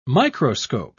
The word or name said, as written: microscope